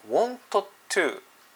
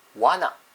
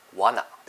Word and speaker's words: wanna: で今回はこの want to というところをかっこよく発音する方法をお伝えしていきたいと思います。それは want to というのではなくて wanna というふうに発音します。 wanna です。